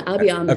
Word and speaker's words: Abiy Ahmed: how do I pronounce it? – Yeah, Abiy Ahmed. Okay, Abiy Ahmed.